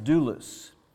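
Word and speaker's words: doulos (δοῦλος): In fact, the word slave, the Greek word, is doulos, and you spell it D-O-U-L-O-J, but it's pronounced doulos, and that means slave.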